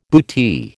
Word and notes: bootee: American English

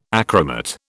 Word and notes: achromat: British English

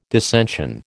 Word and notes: dissension: American English

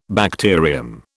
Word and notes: bacterium: British English

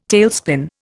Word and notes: tailspin: Indian

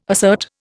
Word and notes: assert: Indian

